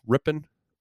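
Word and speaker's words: Rypien: Am I saying that right? Rippon? – Yeah.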